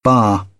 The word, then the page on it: bar: British English